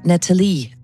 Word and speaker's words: Natalie: Trump kalder hende Natalie. Han udtaler det på fransk. Det er der ikke nogen grund til, hun er ærke-amerikaner.